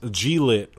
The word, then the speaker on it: Glit: Because it's not a C, it's a G. Unless it's actually pronounced G-lit. – No. – Which is like G-unit. – They did say that it was pronounced glit.